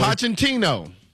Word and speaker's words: Pochettino: Pochettino.